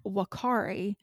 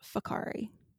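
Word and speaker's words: Whakaari: It's spelled with a W. So it does not look like it looks like I'm pronouncing it completely wrong. It's spelled like Wakari, but it is pronounced Fakari.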